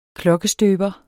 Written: klokkestøber

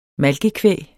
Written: malkekvæg

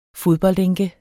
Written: FODBOLDENKE

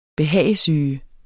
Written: behagesyge

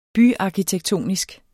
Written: byarkitektoniske

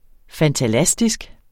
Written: fantalastisk

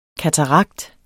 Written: katarakt

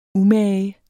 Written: umage

